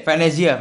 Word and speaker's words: Venezia: Bacanya gimana ya? Venice atau Waduh. Venice. Venezia, Venezia. – Venezia. Venezia. Nah, jadi dia tuh emang kayak uh, di dekat Venezia, tapi uh, dia tuh supporter Spal, dia hmm.